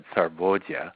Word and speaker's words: sarvodaya: In modern day Hindi, you'd pronounce that sarvodaya.